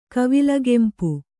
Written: ಕವಿಲ್ಗೆಂಪು

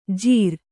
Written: ಜಿರಾ

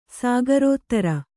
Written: ಸಾಗರೋತ್ತರ

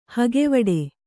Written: ಹಗೆವಡೆ

